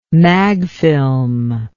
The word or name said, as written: magfilm